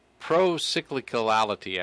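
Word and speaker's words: procyclicality: Pro-cyclicality, actually, is the way it's pronounced.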